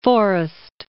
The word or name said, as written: Forrest